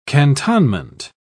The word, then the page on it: cantonment: British English